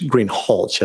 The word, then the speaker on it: Greenhalgh: Officer Greenhall, I believe I'm mispronouncing your name. It's Greenhalgh, isn't it? – Correct.